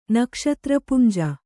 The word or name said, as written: ನಕ್ಷತ್ರಪುಂಜ